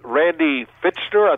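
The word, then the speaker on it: Randy Fichtner: To Randy Fitchner, I think.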